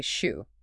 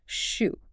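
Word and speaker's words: shu (书): You hear a new Mandarin word, let's say shu, which means book. Pinyin gives you that written representation of that sound, shu.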